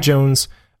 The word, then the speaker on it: Jonze: Spike Johnson, you pronounce that Johnson? Spike Jonze, uses its just barely sci-fi scenario to impart wryly funny wisdom about the state of modern human relationships.